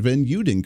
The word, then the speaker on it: Van Uden: Excited to have on the program here with us, and I swear I'm going to pronounce her name properly, Kristen Van Uden.